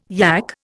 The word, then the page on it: yak: Indian